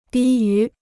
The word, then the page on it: 低于: dī yú